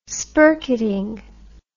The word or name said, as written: spirketting